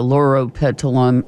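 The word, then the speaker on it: Loropetalum: Not with knockout roses or lauropetalum. – Okay. – Neither of those. Neither the lauropetalum. And I'll spell it for you. L-O-R-O and then petal and then U-M.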